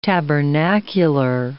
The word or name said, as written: tabernacular